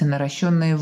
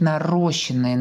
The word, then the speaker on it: наращенные: Мы говорим о том, что в корнях растращрос как раз-таки перед буквами ст и щ пишется буква А, но мы очень часто можем услышать нарощенные, нарощенные. Это, конечно же, неверно, потому что они должны быть наращенные. Тут ударение ударение другое, ну и, соответственно, правописание.